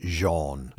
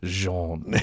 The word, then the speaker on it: genre: They might look at it and say, Genre? – Genre. – It's G-E-N-R-E. I love the way D.A. Carson pronounced it, because he speaks French fluently. – Oh, he right. – Jean. – Jean.